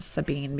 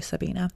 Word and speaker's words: Sabine: So I keep reading it as Sabine because it's spelled that way, but it is actually Sabina. – Is there an A at the end? – No, it's S-A-B-I-N-E.